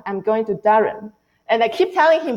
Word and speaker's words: Durham: There's a fun story because the Duke is in North Carolina and the city is called Durham, B-U-R-A-M. And then before I came here, I searched for the pronunciation, and then the English pronunciation is Durham.